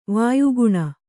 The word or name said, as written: ವಯೋಗುಣ